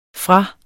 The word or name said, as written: fra